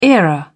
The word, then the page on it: era: British English